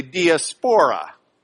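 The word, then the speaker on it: diaspora (διασπορά): The Greek word is diaspora. They just pronounce it a little bit differently, diaspora.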